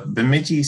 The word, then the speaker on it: Bemidji: Yes, that is Bemidji State University, okay.